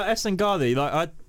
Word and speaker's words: Esengali: No, Esengali. It's spelt in a pretty phonetic way. See, I think I've done that one quite good.